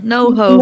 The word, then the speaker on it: NoHo: La escena de Noco. ¿Ahora lo dije bien? – No, Noho. – Noho. – Con H. – Noho. – Es como el Soho, pero Noho.